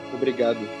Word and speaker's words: Obrigado: Obrigado. – Oh, of course, I know that. Okay, obrigado.